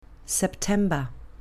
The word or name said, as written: september